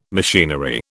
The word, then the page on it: machinery: British English